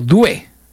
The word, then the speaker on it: Doué: La pronunciación ha sido Doué. Porque son medio franceses estos muchachos de Costa de Marfil, ¿ya?